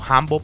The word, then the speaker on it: Hambo: De igual forma nos consigues en Instagram, me buscas como Hambo PR, Hambo se escribe con H y con eso lo tengo.